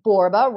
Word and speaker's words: Borba: So it's Michelle, and my last name, Borba, rhymes with Zorba.